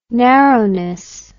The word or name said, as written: narrowness